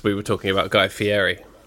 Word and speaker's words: Fieri: You got to pronounce it Fieri.